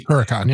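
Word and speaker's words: Korakuen: I hope I'm saying this right, Currican Hall, which is crazy. – Currican, yeah.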